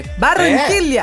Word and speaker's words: Barranquilla: Nossa, mas que pronúncia, hein? Não, fala de novo aí que ficou perfeito. – O nome da cidade? – Barranquilla. É. Ó. É. Oh. Que perfeição, adorei. Bom